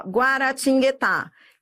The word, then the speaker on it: Guaratinguetá: Adriana, Guaratinguetá, ó, Guaratinguetá, esse guá, eu pronuncio, não é? É, você pronuncia esse U, ele não é um dígrafo, se ele não é um dígrafo, então ele é um ditongo, entendeu? Porque é uma, ele faz um som, esse U faz som,